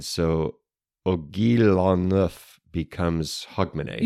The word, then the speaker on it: Hogmanay: H-O-G. – M-A-N-A-Y. – Okay. Hog, man, A. – Yeah. That's it. – Hogmanay.